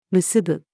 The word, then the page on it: 結ぶ: female